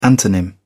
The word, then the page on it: antonym: British English